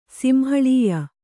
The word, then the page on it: ಸಿಂಹಳೀಯ: simhaḷīya; Kannada